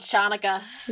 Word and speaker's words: Chanukah: So I was just there in this class of like 60 girls trying to like correct the pronunciation so people weren't saying like sh instead of ch, and like yeah. No one could actually say ch. – Good old Chanukah.